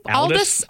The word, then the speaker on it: Aldous: Am I saying that correctly? – Aldous, yeah.